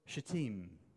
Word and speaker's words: Shittim: Then Joshua, son of Nun, secretly sent two spies from Shittim.